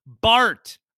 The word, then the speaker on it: Bart: Bart, B-A-R-T, Bart.